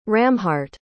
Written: Ramhart

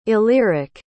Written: illyric

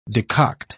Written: decoct

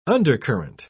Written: undercurrent

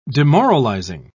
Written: demoralizing

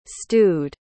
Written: stude

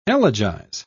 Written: elegize

